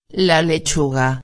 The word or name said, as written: la lechuga